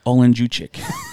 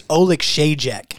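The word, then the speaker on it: Oleksiejczuk: I think it's Oleksijek.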